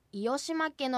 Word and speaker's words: いおしまけ: はい、その通りですが、むかわではいおしまけはいおしまけのように間に Y を入れて発音されることが多いようです。